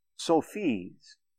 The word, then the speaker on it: Sufi: Muslim mystics were called Sufis. S-U-F-I-S. So the pronunciation, it's pronounced as Sufi.